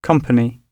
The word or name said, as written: company